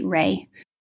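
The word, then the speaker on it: Reay: My handle is at Emma Joy Ray.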